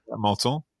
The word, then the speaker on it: Menton: À Menton.